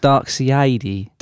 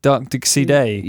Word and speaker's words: Darkseid: It's actually pronounced Darkseid. – Darkseid.